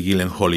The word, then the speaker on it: Gyllenhaal: Pero esa es una película dirigida por Maggie Gallagher, que es la, bueno, la hermana de Jay Gallagher, que lo he pronunciado mal, ¿no, no, no, no hay tanta diferencia con Maggie Gyllenhaal y Jake Gyllenhaal.